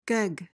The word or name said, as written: gegg